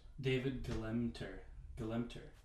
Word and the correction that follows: Gelernter: That's not an M, that's an R. Gelernter. – Gelernter.